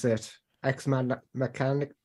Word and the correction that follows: Machina: Machina. It's Machina.